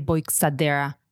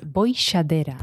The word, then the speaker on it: Boixadera: I've had normally people say Boixadera. That's not correct. It's Boixadera.